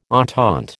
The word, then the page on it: entente: American English